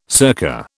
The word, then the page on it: circa: British English